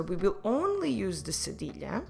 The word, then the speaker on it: cedilha: C-E-D-I-L-H-A. Cedilha. And we will use it only to soften the C when it's followed by the vowels A, O, U.